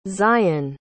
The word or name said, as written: zyon